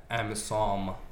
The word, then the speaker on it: AMISOM: Amazon? – AMISOM. Not Amazon, the Jeff Bezos. – Wait, spell it. – AMISOM.